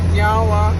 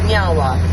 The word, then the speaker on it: nyawa: So, in our language, we say nyawa, which means thank you. – Nyawa. – Nyawa. – I said it right? – Yeah, you did.